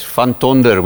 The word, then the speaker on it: Van Tonder: That's great. Van Tonder, we would say.